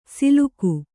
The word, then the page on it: ಸಿಲುಕು: siluku